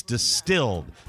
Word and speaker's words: DSTLD: Distilled is spelled D-S-T-L-D. It's Distilled.